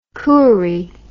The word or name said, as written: Koori